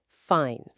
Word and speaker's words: fine: Percebe a diferença no som? Fin, fine.